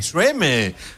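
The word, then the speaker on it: Tremé: Là, c'est beaucoup plus magique que moi, Trémé. Alors que c'est dans Born in Trémé. – Trémé. That's more nice. – Hey! – French pronunciation.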